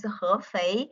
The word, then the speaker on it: Hefei: My hometown, the name of my hometown is, the city name of my hometown is Hefei.